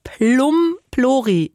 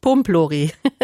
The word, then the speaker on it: Plumplori: So, und jetzt muss ich es nochmal richtig sagen, es ist der Plumplori, nicht Pumplori. Das habe ich gerade falsch gesagt.